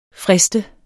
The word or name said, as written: frist